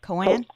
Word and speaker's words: koan: Okay, so you mentioned one koan, right? Am I saying that right? Koan? – Koan.